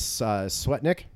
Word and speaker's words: Swetnick: And it turns out Michael Avenatti's new client, Miss Swetnick, mm-hmm. Am I getting her name wrong? – No, you got it.